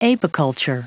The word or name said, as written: apiculture